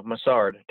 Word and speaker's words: Mussard: Yeah, Mussard. Take the T out of mustard.